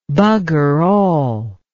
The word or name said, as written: buggerall